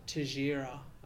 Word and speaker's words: Teixeira: It looks like Teixeira, but I think it's Teixeira, the Brazilian pronunciation.